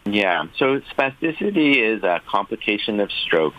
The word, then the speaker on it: spasticity: Okay, so there's something called post-stroke spasticity. Am I pronouncing that right? – Yes, spasticity. That's perfect.